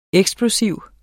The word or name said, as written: eksplosiv